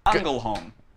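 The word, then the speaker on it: Ängelholm: I know how to say her location because I looked up the pronunciation today. It's Angelholm.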